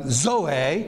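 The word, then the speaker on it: zoe (ζωή): Z-A-O. That's the verb form. Here we actually have zoe. This is Z-A-O, the noun form. Z-O-E, which is a long E. Long O, zoe, pronounced like that.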